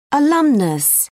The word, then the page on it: alumnus: British English